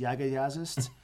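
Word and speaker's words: Jaga Jazzist: So I'm wearing a Def Heaven shirt right now, and I listen to a jazz fusion band called, I think it's pronounced Yaga Yazist.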